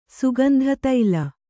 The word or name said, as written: ಸುಗಂಧತೈಲ